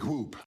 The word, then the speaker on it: whoop: It is, as Peralta would say, no big whoop. – But I actually don't pronounce the H in whoop.